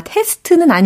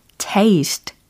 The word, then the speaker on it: taste: 아, 테스트는 아니에요. taste. 이게 정답이 되겠습니다. T A S T E 라고 철자가 쓰여 있고 발음은 테스트가 아니고 taste. taste. taste 라고 하시면 되는 거죠.